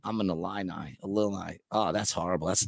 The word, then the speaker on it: Illini: An Illini. – Is that really what it is? – Yeah, Illini. The fighting Illini.